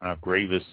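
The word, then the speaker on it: Graves: Barrels of the Gravis region is Gravis, how it's pronounced, were shipped to England during the English reign over France back in the early, between 1152 and 1453.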